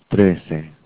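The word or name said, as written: trece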